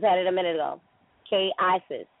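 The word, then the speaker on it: Qué haces: So the H is silent, so pronounce it without the H being sounded. Silent. – K aces. – Well, you said K haces.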